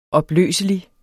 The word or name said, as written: opløselig